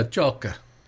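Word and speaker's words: Ciocca: It's C-I-O-C-C-A. Cioca or Cioca? – Is it Italian? – I'm going to go with the second one. – Yeah, Cioca.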